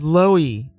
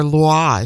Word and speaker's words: Loie: And a track called Loi, or Loie, if you're pronouncing it the French way. L-O-I-E.